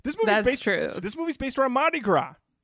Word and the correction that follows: Mardi Gras: Am I saying that right? – You are now. – Oh boy. – Mardi Gras? – Mardi Gras. I think I missed the R the first time.